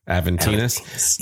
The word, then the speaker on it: Aventinus: Aventinus, yes.